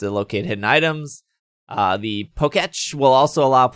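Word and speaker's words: Pokétch: How do you say it? – Poketch. – Poketch. That's a Poketch. – Like Etch a Sketch.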